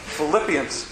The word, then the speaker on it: Philippians: Philippians.